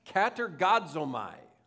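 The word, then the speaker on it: katergazomai (κατεργάζομαι): This word in Greek is pronounced katergazomai. Katergazomai.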